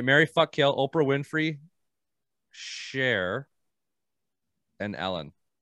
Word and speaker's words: Cher: Like Sonny and Shear? – It's Cher. It's definitely Cher. – It's definitely Cher. – You know how loud my fiance is going to laugh when she hears this? Because I mispronounce fucking everything. – How did you spell it? – C-H-E-R. – Yeah, that's not even spelt Sheer. – Sheer would be S-H-E-R-E.